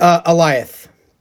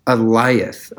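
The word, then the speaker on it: Alioth: I would say the Lyth. That's how you say it, right? From Loki? – Is it Aliath? – Aliath. Okay. – Like Goliath, but it's just Aliath.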